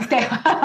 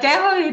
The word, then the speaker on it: Tejo: I know you really have done a very good research on, Tejo, on Colombia. yeah, well, Tejo